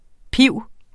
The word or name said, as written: pib